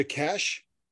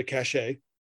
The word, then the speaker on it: cache: He happened to find a cache, or as I would have pronounced it incorrectly as a kid, a cachet,